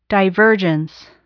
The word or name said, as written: divergence